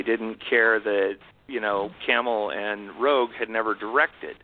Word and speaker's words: Cammell: I grew up pronouncing it Cammell, but I've learned that it's actually pronounced Cammell.